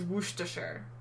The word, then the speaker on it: Worcestershire: The correct pronunciation is Worcestershire.